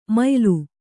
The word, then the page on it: ಮಯ್ಲು: maylu